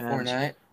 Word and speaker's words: Fournette: I don't know how to say his name. Leonard Fournette. – Yeah, Fournette.